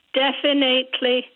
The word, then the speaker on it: definitely: Definitely. And it's wrong, because that middle phrase is finite. So therefore, it should be definitely. There's no A there.